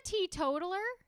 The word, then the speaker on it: teetotaler: No, it's just it's a teetotaler.